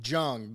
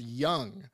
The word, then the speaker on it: Jung: It's Josh Jung. – Not Josh Jung, because I said Josh Jung too, and I thought that's what his name, and it's pronounced Young.